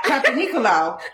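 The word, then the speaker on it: Papanicolaou: That was Dr. Papenzolaou. I don't know how to say his name. Anyways, that's why it's called the Pap. How do you say that name? See? – Yep. – Yeah. – Papenicolaou.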